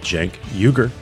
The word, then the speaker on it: Cenk Uygur: I am here at Vox headquarters in New York City with Cenk Uygur, CEO of Young Turks, the Young Turks.